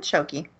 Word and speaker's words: shogi: I thought you said it right the first time. – Shogi. I thought it was a J. Is it a G or a J? – G. – It's a G? Okay, then shogi.